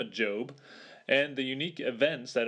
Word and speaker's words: Job: Job is pronounced Job, not Job, as I first initially thought, and I know many other people have as well.